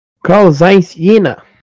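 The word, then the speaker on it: Carl Zeiss Jena: Como que é que se pronuncia? – Karl-Zeiss Jena. Exatamente assim.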